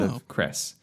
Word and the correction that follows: Ceres: sorry, I think it's pronounced Ceres, just to let you know. Did I miss out a letter?